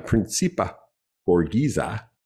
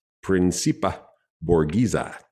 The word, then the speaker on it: principe borghese: principe borghese principe borghese p-r-i-n-c-i-p-e principe borghese B O R G H E S E.